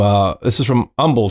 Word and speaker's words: Humble: And this is from Humble, Texas. Just like Houston, you don't pronounce the H.